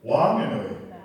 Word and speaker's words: Wanganui: So confident white lady, how do you say Wanganui? – Wanganui. – Wanganui. Wanganui. Okay, that's good. Because like a lot of people say Wanganui, yeah, but that's like a northern dialect.